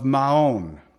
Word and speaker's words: Maon: But David and his men were in the wilderness of Maon. That's how you say it, in the plain of, on the south of, Now, this is literally Yishimon, is how you say it in the Hebrew, Yishimon.